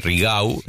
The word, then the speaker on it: Rigau: de la detención de Chocolate Rigau. – Sí, sí. Se pronuncia así, ¿eh? Porque es catalán, no es francés. Digo, porque alguno cuando lo aprende dice, no, Rigau. Se pronuncia así, claro. Rigau, ¿eh? – Rigau.